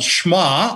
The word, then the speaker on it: Shema: Our call to worship is from Deuteronomy chapter 6, and these verses are in the Hebrew called Shema, or that's how it's pronounced.